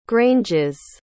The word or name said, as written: granges